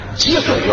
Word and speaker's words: 给水: 啊，车站挤水源，他挤不了。 – 啊，给水，这字读音就念挤，就给字。